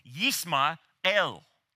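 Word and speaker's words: Yisma'el (ישמעאל): And really, Ishmael, when you pronounce it in Hebrew, is Yisma'el.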